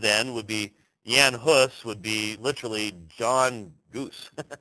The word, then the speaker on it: Jan Hus: in the person of Jan Hus. And some of you have heard the name John Hus. I'm just using the pronunciation that he would have been familiar with.